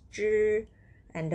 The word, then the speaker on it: 蘸: The word 蘸 has that h as a consonant pronounced as zh, and the vowel is an pronounced as un with the fourth tone put above letter a. This is how you pronounce it. zhan.